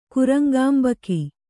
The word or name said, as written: ಕುರಂಗಾಂಬಕಿ